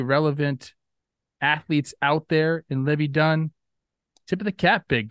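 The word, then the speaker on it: Livvy Dunne: So the internet thinks, and I don't know how to say her name. I believe it's Libby Dunn. – Libby Dunn.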